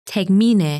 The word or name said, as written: Tegmine